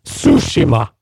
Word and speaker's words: Tsushima: Det uttalas Sushima.